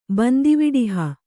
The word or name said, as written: ಬಂದಿವಿಡಿಹ